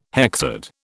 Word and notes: hexad: British English

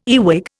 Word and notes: earwig: Indian

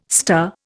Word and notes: star: Indian

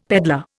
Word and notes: peddler: Indian

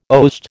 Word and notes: oast: American English

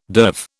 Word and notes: derv: British English

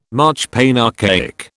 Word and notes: marchpane: British English

